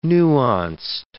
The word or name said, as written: nuanced